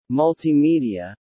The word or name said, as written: Multimedia